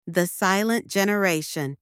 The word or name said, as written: The Silent Generation